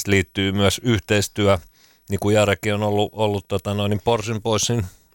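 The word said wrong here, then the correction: Portion Boys: vai mitä täältä lausutaan, Portion poissin. – Portion poissin.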